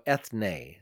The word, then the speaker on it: ethne: And the word that is used for nations is in Greek, E-T-H-N, and then the long form of E, which you pronounce in Greek, ne. So ethne, the mm-hmm.